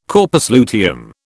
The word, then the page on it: corpus luteum: British English